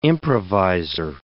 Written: improvisor

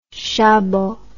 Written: chabot

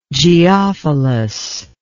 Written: geophilous

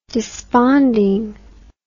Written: desponding

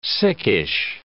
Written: sickish

